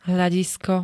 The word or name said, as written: hľadisko